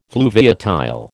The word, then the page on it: fluviatile: American English